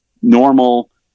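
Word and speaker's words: Nnormal: Normal, which is spelled odd, it's two N's at the beginning, is led or influenced heavily by Kilian Jornet, a good ultra runner, and he is going to promote it.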